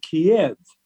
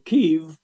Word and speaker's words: Kyiv: For example, we're hearing about the capital city of Kyiv, and some of you may have been pronouncing that Kiev. Well, Kyiv, pronounced K-double-E, and then that's emphasized, and then of EV.